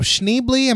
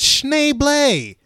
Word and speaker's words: Schneebly: Now, that being said, whenever Joan Cusack asks him, now, Schneebly, am I saying that correctly? And he says, actually, it's Schneebly.